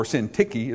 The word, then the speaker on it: Syntyche: I beseech you, Euodius and Syntyche, or Syntyche is how it's actually pronounced.